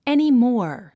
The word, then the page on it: anymore: ènimɔ’ː r; エニモォア